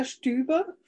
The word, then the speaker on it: Stüber: I'm Julia Stüber from Germany.